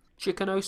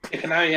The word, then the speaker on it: Chicano: is this pronounced Chicano or Chicano? Chicano sounds right. – Chicano, yeah.